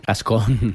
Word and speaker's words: Gascón: Tú imagínate Carla García Gastón. – Gascón. – ¿Cómo se dice? – Gastón era el de La Bella y la Bestia. Gascón.